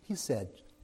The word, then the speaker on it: chesed: If you look at the Hebrew words involved, we look at mercy, and it is spelled C-H-E-S-E-D, but it's pronounced hesed.